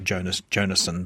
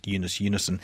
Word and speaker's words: Jonas Jonasson: It looks like Jonas Jonasson, but I gather it's pronounced Jonas Jonasson.